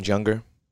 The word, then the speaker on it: Junger: So you've heard of the author, Sebastian Junger? – No. – No. Mm-mm. So how do you spell that last name? – J as in Juliet, U-N-G-E-R, like the word hunger, but with a J.